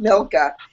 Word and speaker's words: Milka: I used to call her Micah until someone was like, there's an L. And I was like, you know what? In my head... Fine. Yes, it's pronounced Milka.